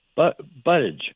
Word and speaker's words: Buttigieg: I do have a long-shot interest in one candidate who's the most unusual in the field, a guy named Pete Buttigieg, B-U-T-T-I-E-G-I-E-G, a very strange name. Buttigieg is how you pronounce it.